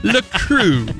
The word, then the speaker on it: LaCroix: LaCroix.